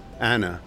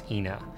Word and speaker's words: Ina: Anna. – Ina. – Ina. – It's always been Ina as long as I've been here. – The road that everyone calls Ina is correctly pronounced Ina.